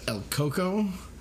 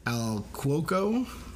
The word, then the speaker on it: El Coco: And this is the legend of El Coco, which is also Al Cuoco, and it's pronounced a few other ways also.